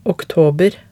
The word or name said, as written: oktober